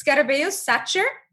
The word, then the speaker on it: Scarabeus Sacer: Okay, so first let me talk about the pronunciation, because nobody knows how to pronounce it. And that's always something that people, like, they either avoid saying it or they're just like, well, how do you say it? So basically it's Scarabeus Sacer.